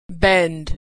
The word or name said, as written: bend